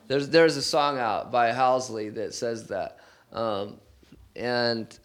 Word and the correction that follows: Halsey: I think I said her name right, Halsey.